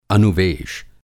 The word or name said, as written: अनुवेश